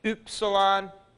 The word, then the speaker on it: upsilon: We get it in words like hymn, H-Y-M-N. And that upsilon is pronounced with that umlaut, U, U. It's upsilon, U.